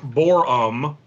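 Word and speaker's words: Boreham: It's B-O-R-E-H-A-M, but it's pronounced Borum, mm-hmm.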